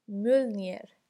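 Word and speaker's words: Mjölnir: تلفظ اسمش یه ذره سخته تلفظ درستش اینه مولنیر.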